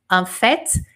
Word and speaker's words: en fait: Com F-A-I-T, como vocês estão vendo aqui, tá bom? Como é, e só que, só que eu, pronuncio esse T. En fait, como eu pronunciei no ditado, en fait, e eu falo T.